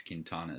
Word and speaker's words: Quintana: it's Quintana, not Quintana. – See, I almost went with that, but you know what? I give up. – It's and fine. Baseball References pronunciation guide seems to show that it's Quintana as well.